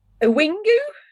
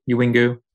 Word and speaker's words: Uwingu: A wingu? – How's that for a pronunciation, Eian? – It sounds right. – Uwingu.